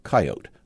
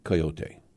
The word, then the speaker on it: coyote: But most touching, I think, is To a Captive Coyote, and Gannon insists that in his part of the plains we must say it coyote, two syllables, not coyote.